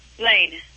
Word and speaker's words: Blayne: Blaine. B-L-A-Y-N-E.